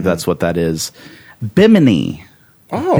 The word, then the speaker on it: Bimini: thought it was like bikini. Yeah, it's not. It's not. It's Bimini.